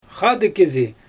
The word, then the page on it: xaadɯɣ kɪzɪ: Xyzyl